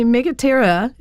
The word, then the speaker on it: Megaptera: And actually Megaptera, if you think about ptera, is the silent P in there, and pteranodons and pterodactyls, means big wing.